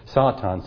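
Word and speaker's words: Satan (שָׂטָן): And actually in Hebrew, it's if I'm saying it right, Satan. S-A-W-T-A-W-N is the phonetic spelling of it. Satan.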